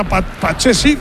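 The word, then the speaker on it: Pathé Ciss: ¿Ha visto cómo se dice ahora? Patecís.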